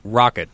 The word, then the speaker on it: roquette: It's rocket. – Rocket. Okay, I'm mispronouncing that. So what is that? – It's the salad.